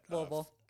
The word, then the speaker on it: Louisville: Wear a wig, but like one time in Louisville, downtown Louisville, when we were at Louisville, gotta say that right.